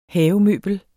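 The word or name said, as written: HAVEMØBLET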